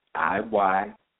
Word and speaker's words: Iyanla: I have trouble pronouncing her first name. Is Inyanla, Iyanla Vanzat.